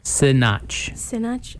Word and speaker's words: Sinach: Is her name pronounced Sinach? – Sinach.